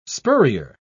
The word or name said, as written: spurrier